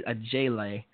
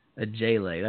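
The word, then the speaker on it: Ojeleye: Semi Olaoje, actually a Jele, spelled O-J-E-L-E-Y-E, a Jele. That's how you say it too.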